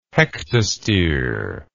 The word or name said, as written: hectostere